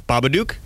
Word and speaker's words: Babadook: Babadook is supposed to rhyme with the words look and or book, right?